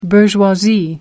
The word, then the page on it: bourgeoisie: American English